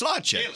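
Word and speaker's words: Sláinte: Slotcha. – Slotcha. It's how you say cheers in Irish. – Oh, cheers. Slotcha.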